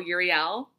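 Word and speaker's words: Euryale: And then we have Uriel. I always say her name wrong. I mean, I always say her name wrong since I started doing the research for this, but it is E... U-R-Y-A-L, Uriel, and her name means far roaming, and she is known for her bellowing and fearsome cries.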